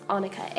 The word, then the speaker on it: Annika: So if I didn't say my name is Annika. And it's not O-N-I-K-A, unlike my bishop may think at times. It's Annika, A-N-N-I-K-A.